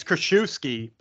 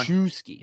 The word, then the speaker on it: Krzyzewski: Is it like Mike Krzyzewski? Is that how you say it? – I think it's Krzyzewski, but I could be wrong. – Krzyzewski, yes.